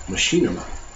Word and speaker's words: Machinima: He is partnering up with Machinima. We agree that's how they say it.